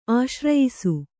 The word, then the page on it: ಆಶ್ರಯಿಸು: kannada